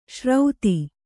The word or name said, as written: ಶ್ರೌತಿ